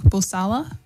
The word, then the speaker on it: Bulsara: And that was, I think it's pronounced Farrokh Bulsara. Bulsara. – Yes. Okay. – Bulsara? – So okay. – Something like that. Yeah. – He's like steak masala.